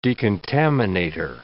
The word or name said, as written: decontaminator